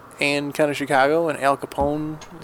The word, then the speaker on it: Al Capone: From Chicago, the great American city of Chicago, home of former great American Al Capone.